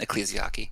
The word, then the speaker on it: Ecclesiarchy: I know it is ecclesiarchy, but Eklasaki.